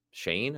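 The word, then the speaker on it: Schoen: Let's remember, these guys have boards, and the Giants, they came out with a story about Joe Shane, I think you pronounce his name, even though it doesn't look like that.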